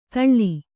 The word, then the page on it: ತಲ್ಲಿ: talli